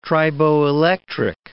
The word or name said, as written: triboelectric